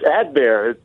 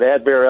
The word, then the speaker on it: Adbert: It's Adbear. It's like B-E-A-R, the last part, Adbear. Yeah, right. – The T is silent, but Adbear Al Zelay,